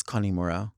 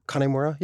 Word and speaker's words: Kanemura: I'm pronouncing it wrong when I say Kanemura. It's Kanemura. – Kanemura, yeah. – Like Connie.